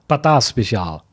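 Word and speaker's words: patat speciaal: Ich habe ja vorhin auch erst das erste Mal in meinem Leben Patata Special, nee, sag du es nochmal. – Patata Special. – Ja, genau, das war die richtige Special, richtige holländische Aussprache hier.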